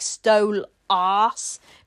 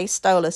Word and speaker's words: Stolas: I know she's a Brit and indeed the only truly British cast member, but I'm a Brit and I don't pronounce it like that. I say Stolas, so I'm convinced that's another way of her getting at him.